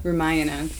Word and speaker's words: Ramayana: Because I did my 300-hour teacher training at Cambia last year, and so they had us read this book, the Ramayana. Am I saying that right? – Ramayana.